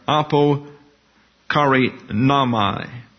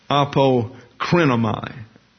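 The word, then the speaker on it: apokrinomai (ἀποκρίνομαι): We have apo kari namai, better pronounced, apokrinomai, and the accent on the wrong syllable.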